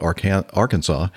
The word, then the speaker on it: Arkansas: But in Kansas, the river that is named for, most people call it Arkansas. What do you call the river there in Kansas? – You said it right. You said it right Arkansas. – The first time.